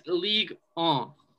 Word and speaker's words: Ligue 1: It's Ligue 1.